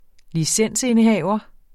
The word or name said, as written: licensindehaver